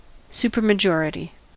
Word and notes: supermajority: American English

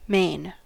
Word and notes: mane: American English

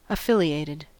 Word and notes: affiliated: American English

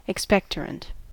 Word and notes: expectorant: American English

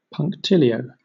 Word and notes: punctilio: British English

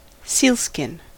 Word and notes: sealskin: American English

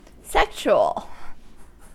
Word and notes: sexual: American English

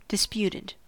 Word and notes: disputed: American English

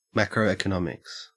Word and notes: macroeconomics: Australian English

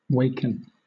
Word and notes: waken: British English

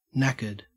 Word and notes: knackered: Australian English

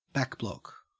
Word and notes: backblock: Australian English

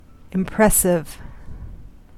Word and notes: impressive: American English

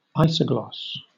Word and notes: isogloss: British English

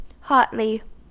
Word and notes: hotly: American English

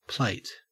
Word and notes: plate: Australian English

